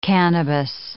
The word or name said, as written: cannabis